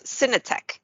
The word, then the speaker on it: Synetek: And on that worldwide ranking, when there's tens of thousands of us out there, Synetic was pretty high on that list. You guys made what, number 13? – Yeah, number 13. And it's Synetec. – Synetec. I mispronounce it every time.